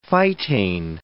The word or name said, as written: phytane